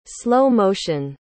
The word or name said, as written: slow-motion